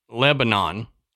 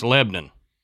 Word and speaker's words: Lebanon: And the other one that gets me is it's not Lebanon, it's Lebanon. – Oh, yeah, right. – When I first moved up there, I'm like, oh, Lebanon, Tennessee, and everybody was like, where?